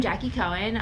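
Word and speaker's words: Jaqi Cohen: Well, hi, I'm Jackie Cohen. I am political correspondent for Let Your Voice Be Heard. You can follow me on Twitter, on Instagram at Jackie Cohen.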